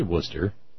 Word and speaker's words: Worcester: See, I can say Worcester.